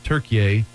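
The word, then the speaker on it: Türkiye: And I think in their dialect, we say Turkey and they said Turkiye. – Right. – Just the way they pronounce it.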